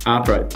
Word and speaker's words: Ahpra: Ahpra. A lot of people will say Ahpra, but we like to say Ahpra so as not to confuse it with the prudential regulator.